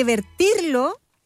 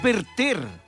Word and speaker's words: verter: No se dice vertir. Es verter. Verter es un verbo irregular que significa derramar líquido, vaciar el contenido de un recipiente o también traducir. Se dice verter.